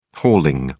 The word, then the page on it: hauling: British English